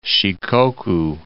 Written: Shikoku

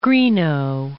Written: Greenough

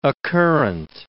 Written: occurrent

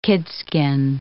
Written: kidskin

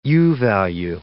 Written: U-value

